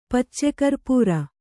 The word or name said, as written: ಪಚ್ಚಕರ್ಪುರ